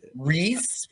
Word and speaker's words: Rhys: So it's R H Y S. – It's Reese Owls, right? Reese, Reese, oh, R A, and then it's I F A N S.